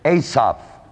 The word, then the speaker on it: Asaph: One of those seven is a man by the name of Asaph. It's spelled A-S-A-P-H.